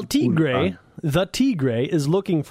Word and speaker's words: Tigre: It says Tigre, pronounced Tigre, Spanish for tiger, natch.